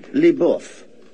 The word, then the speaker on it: LeBeouf: It's Richard LeBeouf. – LeBeouf? – It's LeBeouf. It's like a French, Canadian-French. Richard LeBeouf.